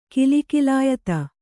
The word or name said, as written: ಕಿಲಿಕಿಲಾಯತ